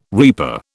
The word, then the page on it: reaper: British English